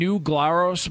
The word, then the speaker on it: New Glarus: Spotted Cow is what I've been told from New Glarus Brewery.